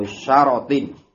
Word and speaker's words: syarat: Al-asyratu jam'u syaratin, bacanya syarat.